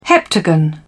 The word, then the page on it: heptagon: British English